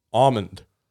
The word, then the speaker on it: almond: Almond. – Say it slower. – You don't pronounce the L. – Yeah, where's the L? No, almond. It's a silent L. No one not says the L.